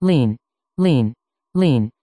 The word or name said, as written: Lean